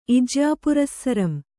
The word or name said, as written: ಇಜ್ಯಾಪುರಸ್ಸರಂ